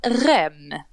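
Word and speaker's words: Reims: Men han den unga killen från, vad säger man, Rem, det är så man uttalar det, va? – Ja.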